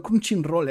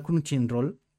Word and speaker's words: Crunchyroll: Está en el crunching Roll este, o no sé pronunciarlo nunca. – Sí, sí, lo ha dicho bien, lo ha dicho el, bien. El Crunchyroll.